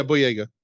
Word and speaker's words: Boyega: John um, Boyega. Do you think that's how he, how he pronounces his name? Yeah, Boyega.